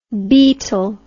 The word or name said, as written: beatle